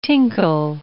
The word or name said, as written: tinkle